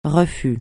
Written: refus

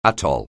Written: atoll

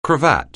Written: cravat

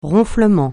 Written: ronflement

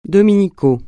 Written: dominical

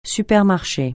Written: supermarché

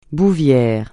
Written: bouvière

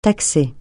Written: taxer

